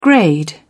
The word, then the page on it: grade: British English